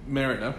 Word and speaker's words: Merida: Meridia. – Who's that? Where's she from? – It's Merida. I mispronounced. What did I say? Meridia? – Meridia. – Yeah, that's hilarious. – And Merida? – Merida from Brave.